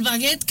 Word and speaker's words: baguette: Y el baguette, que tampoco lo pronuncio muy bien. ¿Cómo se pronuncia? – Baguette. Está perfecto. – Baguette. Es que tiene otro tono.